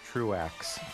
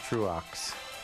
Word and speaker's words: Truax: Tried to look up the pronunciation, which I'm probably getting wrong, but the pronunciation, the online pronunciation thing that I got told me to pronounce it as Truax. Truax, perhaps. So that's what I'm gonna do.